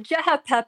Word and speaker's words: CHP: The CHP party, because it's CHP in English, but C's are pronounced like J's in Turkish, stands for the Republican People's Party.